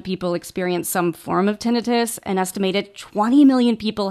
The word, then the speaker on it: tinnitus: It's a word that I think a lot of us pronounce as tinnitus, but I guess tinnitus is the formal way to pronounce it, ringing in the ears.